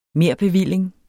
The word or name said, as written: merbevilling